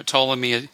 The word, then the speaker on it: Ptolemy: You know, Ptolemy is spelled with a silent P,